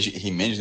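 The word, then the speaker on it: Jiménez: Ou seja, essa, essa, João, só essa, essa questão de Jimenez, é Jimenez, né? É. Essa pronúncia espanhol.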